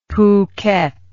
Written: phuket

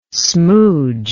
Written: smoodge

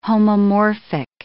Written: homomorphic

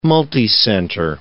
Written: multicenter

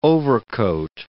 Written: overcoat

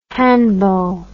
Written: handbow

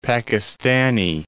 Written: Pakistani

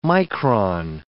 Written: micron

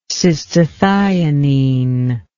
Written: cystathionine

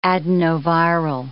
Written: adenoviral

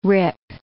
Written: rip

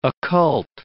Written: occult

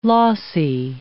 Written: lossy

